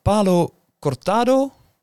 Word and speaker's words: Palo Cortado: En hier ga ik waarschijnlijk eh, de, de, de, de fout in met Palo Cortado en Oloroso sherryvaten. – Ja. – Heb ik het goed uitgesproken? Heel goed.